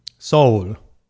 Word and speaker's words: Seoul: Is the city Seoul pronounced that way in Korean? – Seoul. So it's very close.